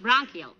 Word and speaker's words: bronchial: You heard Doc Gamble tell me if I didn't cut out cigars, my Chronicle tubes would get stopped oh. up and I would. Oh. the word is bronchial, dearie, and it's pronounced bronchial.